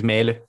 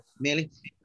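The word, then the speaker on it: Mæhle: Defensively, I've got Laporte, Emerson, Chiellini, Melly or Mali? Who's it? How do you say the Danish boys at Mali? – I think it's Melly. – Melly.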